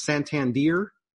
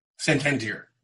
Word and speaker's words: Santander: In my ethnocentric pronunciation guide, it's Santander. – Santander.